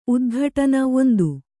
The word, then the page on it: ಉದ್ಘಾಟನೆ: udghāṭane; kannada